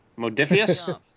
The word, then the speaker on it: Modiphius: It's from Mophidius. Mof- uh, Modiphius. – Modiphius. More. I want. always want to say Morpheus. Yeah. It's Modiphius.